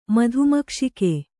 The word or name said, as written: ಮಧುಮಕ್ಷಿಕೆ